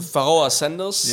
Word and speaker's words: Pharoah Sanders: Pharoah Sanders.